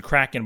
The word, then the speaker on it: Kraken: Correct pronunciation, Kraken, by the way.